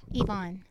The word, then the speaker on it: Yvonne: Yvonne.